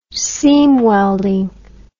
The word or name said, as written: seam welding